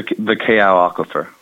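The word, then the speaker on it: Kau aquifer (Kaʻū): And I know how to say Waiakea, but I don't know how to say the is it K-E-A-A-U, the aquifer at the bottom of Mauna Loa Volcano? – Yeah, it's the Kau aquifer.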